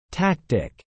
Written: tactic

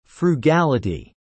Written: frugality

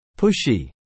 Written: pushy

